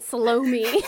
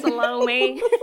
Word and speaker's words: Salome: I remember growing up hearing it, Salome. – 100%. When you said the name at first, I was like, that sounds kind of, oh yeah, no, that's how you say it.